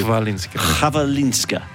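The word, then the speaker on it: Chwalińska: So this Maya, the correct pronunciation is phonetically H-V-A. L-E-E-N-S-K. So Chvalinska. Chvalinska.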